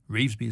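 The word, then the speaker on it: Revesby: Reevesby, Mark, Reevesby is the way you always pronounce it.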